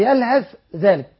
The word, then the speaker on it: يلهث ذلك: فمثلوه كمثل الكلب إن تحمل عليه يلهث أو تتركه يلهث ذلك، حفص نعم. يقرأها يلهث ذلك لا ينطق الثاء، ما حكم ورش في هذه الكلمة مثلا؟